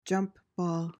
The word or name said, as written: jump ball